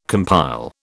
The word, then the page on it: compile: British English